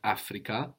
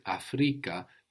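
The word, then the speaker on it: Afrika: So not Africa, but Africa, the accent on the penultimate syllable, Africa.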